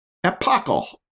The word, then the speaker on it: epochal: But that moment when I first hit the keys to spell out the end was epochal. Epochal. Do you know how to pronounce it? Epochal.